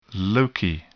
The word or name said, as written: loci